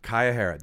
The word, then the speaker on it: Kayahara: Kayahara.